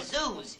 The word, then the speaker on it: Zeus: See, his last name is Zeus. You know, rhymes with booze.